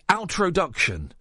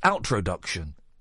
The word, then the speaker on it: outroduction: The outroduction. It's like the opposite of introduction. It's outroduction.